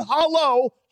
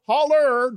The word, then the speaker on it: holler: Drive up the holler, not hollow, holler.